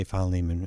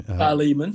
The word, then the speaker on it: Philemon: How do you say Philemon? – Philemon.